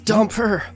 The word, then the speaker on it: Dumper: I don't know why you started working with Damper, I think is how you pronounce his name correctly, Damper. And I feel like, it's Dumper.